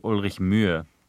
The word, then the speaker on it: Ulrich Mühe: I mean, the sound, which is in his last name, doesn't actually exist in the English language, so it's actually Ulrich Mühe.